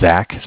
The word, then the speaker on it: Zach: My name is Zach, spelled Z-A-C-H. – Calling from where? – Bank of Cardiff. Cardiff is spelled C-A-R, like car.